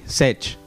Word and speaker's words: Sech: Eh, Sech. Sí, Sech.